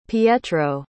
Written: pietro

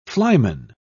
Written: flyman

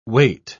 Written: weight

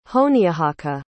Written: honiahaka

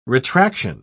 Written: retraction

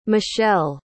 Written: mishael

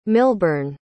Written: milburn

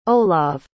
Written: olav

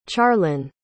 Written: charlyn

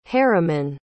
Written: harriman

